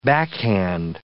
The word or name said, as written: backhand